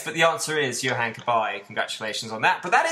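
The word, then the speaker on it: Cabaye: Yeah, his first name rhymes with Oh Hi. – Boyce? – Boyce, yes, Boyce. – Johan Kabay? – You are correct, sir.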